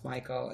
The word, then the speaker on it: Mykal: Look up Mykal. I think it's pronounced Michael.